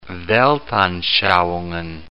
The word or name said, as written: weltanschauungen